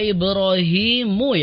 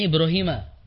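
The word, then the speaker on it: إِبْرَاهِيمُ: Mbak Tasya tadi di wa idh qala ibrahimu ya harusnya ya. Tadi bacanya Ibrahima. Ya harusnya Ibrahimu. Di situ dhammah ya Mbak Tasya, kurang teliti di situ. Wa idh qala ibrahimu rabbi, bukan Ibrahima.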